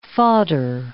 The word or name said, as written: fodder